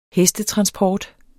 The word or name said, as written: hestetransport